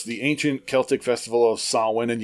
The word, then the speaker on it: Samhain: All right, the tradition originated with the ancient Celtic festival of Samhain, spelled S-A-M-H-A-I-N, and it's pronounced Samhain. – It's pronounced Samhain, but yes, it's spelled Samhain.